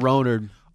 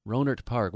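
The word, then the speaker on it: Rohnert Park: I didn't know that's spelled Ronard. R O H N E R T. Roner Park. Or Roner Park. Ronert. – Ronert Park.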